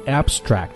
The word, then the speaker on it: abstract: 另外我们要注意 abstract 这个词，它做形容词的时候重音在第一个音节读作 abstract, 做动词的时候重音在第二个音节 abstract, OK?